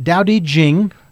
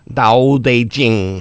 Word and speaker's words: Tao Te Ching: Now it's pronounced Tao Te Ching. – Tao Te Ching.